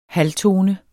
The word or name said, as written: halvtone